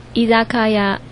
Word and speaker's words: 居酒屋: 嗯，居酒屋日文怎么念？ – 伊扎卡亚。 – 嗯，很好，没有错哈。